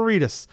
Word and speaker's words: Reedus: God, it rhymes with fetus. Norman Reedus.